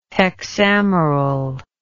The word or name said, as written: hexameral